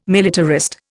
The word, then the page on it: militarist: Indian